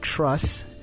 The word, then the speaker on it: Chruss: You heard Truss, and that's spelled C-H-R-U-S-S, featuring Thrust.